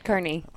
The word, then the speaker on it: Kearney: We'll be cruising through the McCook area for a while, and then we're going to head up to Kearney. Did I pronounce that right? – You did, Kearney.